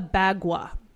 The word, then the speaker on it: bagua: It's called a bagua. Bagua. – How do you spell it? – B-A-G-U-A.